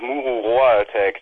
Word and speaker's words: Mururoa Attack: Das spricht niemand richtig aus. Es heißt eigentlich Mururoa Attack. Das ist dieses kleine Atoll da in der Südsee, wo die Franzosen vor 15, 16 Jahren ihre letzte Bombe haben fallen lassen.